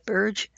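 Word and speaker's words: Birge: Birch, I think that's the right pronunciation, it's B-I-R-G-E, who is a registered nurse with a bachelor's degree from Lakeville School of Nursing, immediately recognized that Katie wasn't having a seizure.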